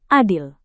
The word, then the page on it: adil: indonesian